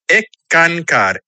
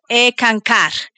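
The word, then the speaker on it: Eckankar: Y hablábamos de que eres miembro de ENCCAR, ¿no? – Se pronuncia ECANCAR, S-K-A-N-K-A-R, significa colaborador con Dios. – Perfecto, ECANCAR.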